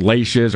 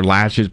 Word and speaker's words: laches: Well, and so I, I, think you know what you're probably thinking of is, is called laches or lashes. People call it, you know, pronounce it it's different ways.